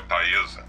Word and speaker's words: Taesa: Pessoal, é Taesa. Só, só, só corrigindo Taesa. Eu falei errado várias vezes o nome, peço perdão.